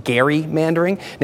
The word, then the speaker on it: gerrymandering: I mean, for instance, did you know it's actually pronounced gerrymandering, named after a founding father named Elbridge Gerry, who first tried the tactic by drawing a map that the press said looked like a salamander?